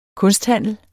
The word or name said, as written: kunsthandler